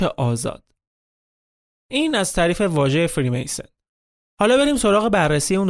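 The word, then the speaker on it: Freemason: فراماسون یا بهتر تلفظش کنیم فریمیسن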